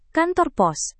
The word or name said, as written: kantor pos